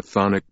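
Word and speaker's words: chthonic: Follow deep into the sunken palace, for here in the darkness the thonic monster waits. – Robert? – Sienna asked uneasily. – What kind of monster? – Thonic, Langdon replied. The CH is silent.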